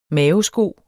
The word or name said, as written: maosko